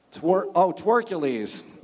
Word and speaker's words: Twercules: Which teammate has the nickname Twerkles? Twercules. I read it wrong. – Who? – Well, I was reading it phonetically. It's Twercules.